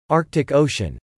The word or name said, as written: Arctic Ocean